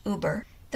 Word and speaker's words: Uber: I'm using VoiceOver Samantha, and it says Uber, as does Eloquence on my computer. However, from what I've heard, it is correctly pronounced Uber.